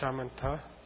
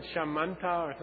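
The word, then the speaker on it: śamatha: In Sanskrit, the Shine is called Shamanta. – Sanskrit has a aspirated dental and an unaspirated dental. So in transcription, you have to write T or TH.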